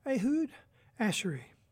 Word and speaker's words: Ehud Asheri: And that was a new release from pianist Ehud Asheri. And it's spelled, first name is E-H-U-D, last name is A-S-H-E-R-I-E.